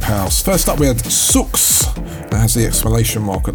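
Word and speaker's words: !Sooks: It's by I Sooks, or just Sooks. You don't pronounce it. The I is actually an exclamation mark.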